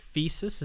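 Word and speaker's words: Theseus: Thesis? Is that the pronunciation? – Yes. – Yeah. – Thesis, yeah.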